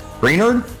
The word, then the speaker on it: Greenard: Greenard? Did I say that wrong? Greenard? – Yeah, you said it wrong. I think Greenard.